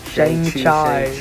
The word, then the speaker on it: Shang-Chi: Shang Chang. Shang Chai. Yep, yep.